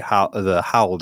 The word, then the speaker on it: Howl: So there's a project that I'm working on with some other very smart people called Howl, which I, because of my pronunciation, H-O-W-L, Howl. – Okay. – Howl, not Howl 9000, is what a lot of people think.